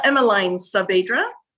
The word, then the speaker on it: Emmeline Saavedra: It's Emmeline Saavedra.